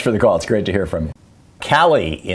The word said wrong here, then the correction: Kylie: if I'm saying that right, in Marietta, California. – Kylie, yes. – Kylie.